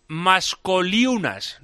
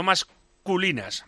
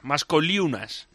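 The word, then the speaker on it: Maskoliūnas: El ayudante de Jaskivicius se llama Darius Mascoliunas, no masculinas, Mascoliunas.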